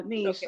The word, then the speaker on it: Nesh: And everybody should know how that's spelled, but if not, G-E-E-K-Y Niche, and it's N-E-S-H. – It's niche, like a long E. I'm sorry. – Yeah, niche. Okay. I'm sorry. Long E there. She's right. So Geeky Niche. And So N-E-S-H at the end of that.